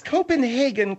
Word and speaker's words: Copenhagen: No, it's Copenhagen. Copenhagen. Or if you will, the Copenhagen. And no, that's not the French. That's the original Germanica, the Saxon pronunciation.